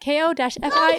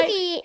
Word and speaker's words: Ko-fi: check out our Ko-fi at ko-fi. – Coffee. Let's drink coffee. – No. it's not coffee. It's Ko-fi. It rhymes with no fee.